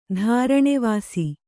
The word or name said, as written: ದಾರಣೆವಾಸಿ